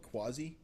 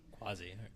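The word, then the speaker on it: CUAHSI: A company called Quasi. – Quasi. – Yeah, and it's spelled funky. It's C-U-A-H-S-I.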